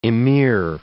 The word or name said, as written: emir